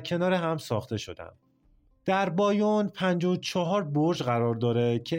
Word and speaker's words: بایون: بعد از مدتی خمرهای محلی که در بازسازی این معبد کمک میکردن بانیان رو به اشتباه بایون تلفظ میکردن که به مرور زمان این اسم یعنی بایون روی این معبد ماندگار شد.